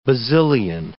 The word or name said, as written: Basilian